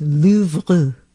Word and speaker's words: Louvre: I definitely don't even come close to speaking the language, but I was corrected that it's Louvre, Louvre, when you want to sound educated about the Louvre.